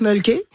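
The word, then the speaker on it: Mölkky: Alors, ça s'appelle le Mulky. On prononce Mulky. – Ouais. – Et c'est M-O-L-K-K-Y.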